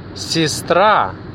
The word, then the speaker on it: сестра: Then, сестра. Сестра. Also quite similar to sister. Сестра. But the stress is on the second syllable.